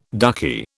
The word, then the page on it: ducky: British English